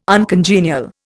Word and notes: uncongenial: Indian

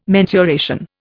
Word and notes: mensuration: Indian

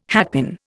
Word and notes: hatpin: Indian

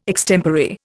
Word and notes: extempore: Indian

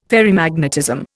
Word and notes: Ferrimagnetism: Indian

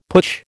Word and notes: putsch: American English

